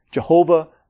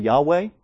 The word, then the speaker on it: Yahweh: That's the older way of pronouncing this name. Jehovah, Yahweh. Scholars now think it's probably Yahweh.